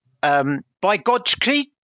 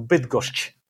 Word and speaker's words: Bydgoszcz: By Gotschke? – The first letter was right, yes. – Okay. – Everything else less so. Bydgoszcz is how you pronounce Bydgoszcz.